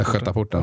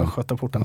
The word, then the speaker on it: Östgötaporten: Nu får du lära dig att uttala här. Du bor i Östergötland. – Ja. – Vad heter det? – Östgötaporten. – Östgötaporten. Ja, tack.